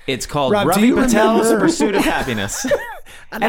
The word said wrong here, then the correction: Ravi: I believe his name is pronounced Ravi.